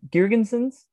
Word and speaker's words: Girgensons: And going back to forwards, I think I just got to go with uh, Zemgus Girgensen. Uh, Girgensons, I think that's how you pronounce it.